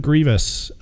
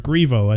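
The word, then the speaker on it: Grevioux: Story is by Kevin Grievous, and he is the guy who plays, or Grievo, I think is how you would pronounce his name, Grievo.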